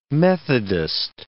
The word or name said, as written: methodist